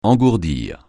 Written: engourdir

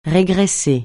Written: régresser

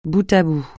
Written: bout à bout

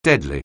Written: deadly

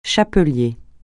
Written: chapelier

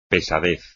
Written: pesadez

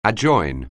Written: adjoin

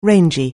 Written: rangy

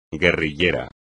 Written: guerrillero

